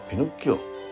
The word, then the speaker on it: ピノッキオ: タリア語から翻訳したものでピノッキオの冒険の題名には、えー、役者中が付けられ日本では今までピノッチオにつなとなっているがそれは誤りであるイタリア語ではピノッキオと発音する